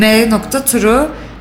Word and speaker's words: M.True: M.True. M Melike'nin M'si, True doğrunun İngilizcesi, telaffuzu M-True, yani Melike doğru.